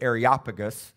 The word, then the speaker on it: Areopagus: Paul is at the Areopagus, or actually Areopagus, is how you say it properly.